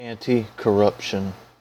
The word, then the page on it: anticorruption: American English